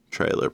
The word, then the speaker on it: Treylor: Now, Trailer Park is spelled T-R-E-Y-L-O-R. Trailer Park.